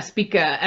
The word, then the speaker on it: Spica: Yeah, Spica, S P I C A is how you spell it.